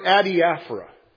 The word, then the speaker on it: adiaphora: I'm going to spell it for you. Then you'll pronounce it a whole lot better. A-D-I-A-P-H-O-R-A. A-D-I-A-P-H-O-R-A. Adiaphora. Say it. Ready? Adiaphora. Much better.